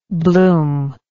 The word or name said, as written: bloomless